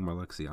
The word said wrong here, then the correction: Marluxia: I'm sorry, Marluxia. I've